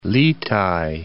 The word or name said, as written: litai